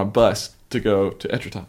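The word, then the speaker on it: Étretat: I don't know how to say this. Etretat? – Etretat.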